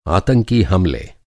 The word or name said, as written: आतंकी